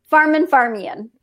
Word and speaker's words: Farmanfarmaian: Farmanfarmaian.